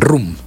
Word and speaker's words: Rom: Rum. Se escribe Rom, creo, ¿no? – Sí. – Sí. Rom, pronuncian Rum.